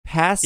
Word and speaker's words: Pansimian: Pansimian.